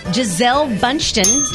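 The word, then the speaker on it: Gisele Bündchen: Giselle Bundchen.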